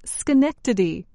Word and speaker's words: Schenectady: This city in New York State is pronounced Schenectady.